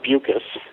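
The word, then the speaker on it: Beukes: Following me will be Lauren Bucus, rhymes with mucus, and I can say that because that's her standard line, and she is a wonderful novelist, South African writer, whose second novel just won the Arthur C.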